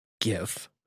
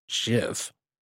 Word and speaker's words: GIF: People who pronounce GIF as JIF probably also have their toilet paper roll feed down the wall instead of the correct way over the top.